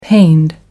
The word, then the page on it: pained: American English